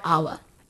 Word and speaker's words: hour: Because the H in hour is silent and it sounds like O-U-R, hour.